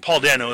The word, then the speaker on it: Dano: Paul Dano is the way it's pronounced.